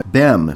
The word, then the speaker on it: Behm: Derek B-E-H-M. Bem, I believe it's pronounced. – All right. – Derek. – Yay. Bem.